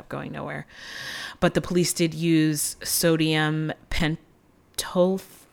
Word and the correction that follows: Pentothal: I don't know if I'm saying that right. It's truth serum. Am I saying that wrong? – No, I don't know. Like what? – Pentothal. Sorry, sodium pentothal.